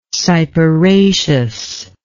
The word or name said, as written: cyperaceous